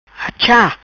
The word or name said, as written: Achá